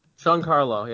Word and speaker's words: Giancarlo: Giancarlo? – Giancarlo, yeah.